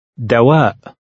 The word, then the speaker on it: دواء: Remember to pronounce the glottal stop at the end of the word. – دواء.